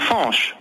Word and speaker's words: Fanche: Soyez le bienvenu, artisan fumeur, gérant du cellier de Fanche, je le prononce bien. – De Fanche. Fanche comme en breton. – D'accord, F-A-N-C-H.